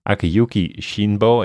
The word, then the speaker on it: Akiyuki Shinbo: Akiyuki Shinbo is.